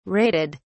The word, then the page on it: raided: British English